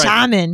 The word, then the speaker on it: Charmin: No, say it right. – Charmin.